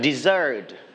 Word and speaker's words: dessert: Dessert. – Dessert. – All right, so dessert, you are going to stretch on the second syllable, dessert.